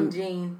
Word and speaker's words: Botham Jean: Botham Jean.